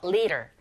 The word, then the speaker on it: leader: 드가 아닌 르에 가깝게 들려서 leader.